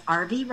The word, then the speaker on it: Arvee: It's my name, so it's Arvie Robinson, and that's spelled A-R-V-E-E.